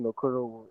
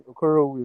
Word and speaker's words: Okorowu: My Twitter handle is Martin Okorowu, M-A-R-T-I-N. Then Okorowu is spelled O-K-O-R-O-W-U.